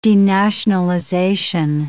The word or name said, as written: denationalization